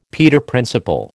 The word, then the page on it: Peter Principle: American English